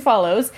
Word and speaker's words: Follows: Oh, her name is actually pronounced Megan, I just found out. So Megan Follows.